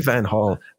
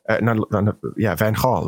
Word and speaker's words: van Gaal: Actually, all G's are H's in Dutch. So he's just the only one who I care to put that pronunciation into, because he's earned it. Like Louis van Gaal, Van Gaal, right? Not Van Gaal.